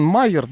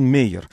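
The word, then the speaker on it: Mayer: Он пишется Джон Майер, да, но как так он из традиционной еврейской семьи очень такой, поэтому он на самом деле читается как Джон Мейер.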